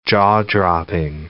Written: jaw-dropping

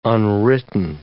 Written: unwritten

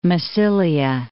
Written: Massilia

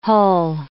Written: hull

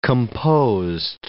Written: composed

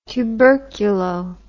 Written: tuberculo

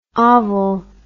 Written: ovel

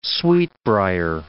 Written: sweetbriar

